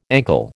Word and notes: ankle: American English

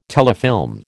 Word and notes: telefilm: American English

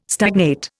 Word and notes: stagnate: Indian

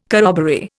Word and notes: corroboree: Indian

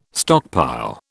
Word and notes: stockpile: British English

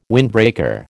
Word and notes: windbreaker: American English

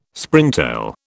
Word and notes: Springtail: British English

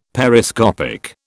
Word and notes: Periscopic: British English

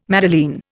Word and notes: madeleine: Indian

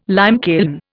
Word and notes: limekiln: Indian

